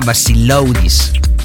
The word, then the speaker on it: Vassiloudis: S-T-E-L-I-O-S, e Stelios, esto es fácil. Y el Baseludis es V-A-S-S-I-L-O-U-D-I-S, ¿ok? Basiludis que se pronunciaría en nuestro idioma Basiloudis.